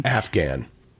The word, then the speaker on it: Afghan: Which I think the fact that you put an extra vowel sound in there is really interesting. – Afghan.